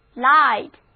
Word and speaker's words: light: 那么灯 light， L-I-G-H-T， light 字母 I 发 I 的本字母音，字母组合 GH 不发音。 Now read after me，就是 light。